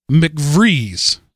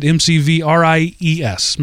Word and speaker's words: McVries: Our next one is comes from McVries. I'm going to assume that's the way you pronounce it M C V R I E S, McVries.